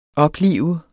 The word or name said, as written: oplive